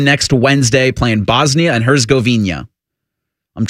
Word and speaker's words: Herzegovina: That's how I've always heard Herzegovina. – It pronounced. That's how, at least in any Olympics, I've always heard them say Bosnia and Herzegovina.